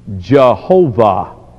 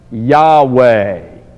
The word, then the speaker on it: Yahweh: The old scholars thought it should be pronounced Jehovah. Modern scholars say, no, that's not quite right. It should be pronounced Yahweh.